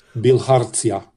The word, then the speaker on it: bilharzia: B-I-L-H-A-R-Z-I-A. Bilharzia.